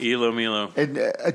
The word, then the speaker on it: Ilomilo: It's called Ilomilo, I guess it's pronounced. – Ilomilo. – Did you know? Were you familiar with it? – No, my daughter told me how to pronounce it.